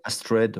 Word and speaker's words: Astread: A-S-T-R-E-A-D. Je sais pas. Astrid. – Astrid, ouais.